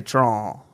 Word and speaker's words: Trahan: Do you say Trahan or Trahan? – I definitely say Trahan, but that's the thing. Like, it all comes out as kind of a squall because of my southern draw. – So how do you spell it? – T-R-A-H-A-N.